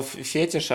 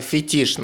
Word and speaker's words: фетиш: Я сказал слово фетиш, а правильно фетиш нас Анастасия научила, так что надо работать над собой. Фетиш, правильно говорить.